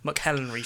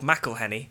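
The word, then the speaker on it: McElhenney: And I think Ryan and Rob, and thank God they say how to pronounce his Rob's surname, because I've been calling him McHenry for years. Rob McElhenny.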